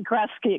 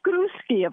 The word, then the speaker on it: Grusky: Well, most people pronounce it Grusky. Excuse the clanking of the barn door there. I just put my goats away. People pronounce it Grusky, but if we want to be true to the old world origins of it, the Polish Jewish name, it's Grusky, of course.